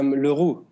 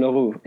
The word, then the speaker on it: Leroux: It's Leroux. – Leroux. – Maxime Leroux, exactly.